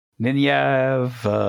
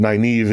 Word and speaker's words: Nynaeve: How is that name pronounced? – Nynaeve. – I've heard so many different versions of it, but Nynaeve is apparently the correct version.